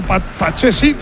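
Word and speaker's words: Pathé Ciss: Bueno, pues la bola que la tiene Pedrosa, tocando cuero para Pache Cis. ¿Ha visto cómo se dice ahora? Pache Cis.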